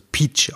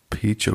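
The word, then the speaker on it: piç: PIC, wenn man das so schreibt, im Türkischen wird das Peach ausgesprochen, was Bastard bedeutet, ja. Peach, okay.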